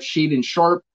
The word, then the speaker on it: Shaedon Sharpe: I have Shaedon Sharpe.